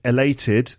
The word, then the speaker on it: elated: So we've got the adjective elated. Elated. Elated. Second syllable. 3 syllables and it's the second syllable.